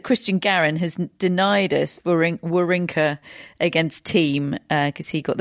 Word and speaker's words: Wawrinka: Yes, I'm pronouncing it Wawrinka. Apparently, we've been pronouncing his name wrong for the past 15 years.